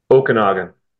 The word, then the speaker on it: Okanagan: So, is it Okanagan? Did I say it right? – Okanagan.